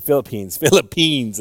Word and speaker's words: Philippines: En los, how do you say, I was about to say Philippians. The, what is it, the place, the country. The Philippines, there you go, Peens. Philippians. 2 Corinthians walked in. At the Philippines, they crucify two people.